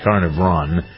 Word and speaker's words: Carnarvon: After discovering King Tut's tomb, Carter and another archaeologist, Lord Carnarvon, is that how you pronounce his name? Looked like carnivore at first, but it's Carnarvon.